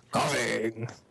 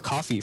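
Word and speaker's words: Koffing: My favorite was Coughing. We just said this word, Coughing. I thought his name was Coffee for a while because he didn't pronounce it right, but it just had that really low rumble.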